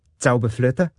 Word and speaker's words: Zauberflöte: Zauberflöte.